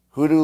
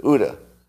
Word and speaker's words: OODA: I'm saying it wrong. Hoodoo loop? Loop? CUDA? OODA. The OODA loop.